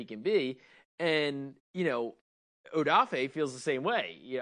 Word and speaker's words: Odafe: I like that he was, like, correcting people on his name. They're like, he's like, Odafe. – Yeah, yeah. – You pronounce it right, mister.